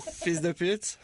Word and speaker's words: fils de pute: Fils de pute.